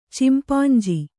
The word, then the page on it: ಚಿಂಪಾಂಜಿ: cimpānji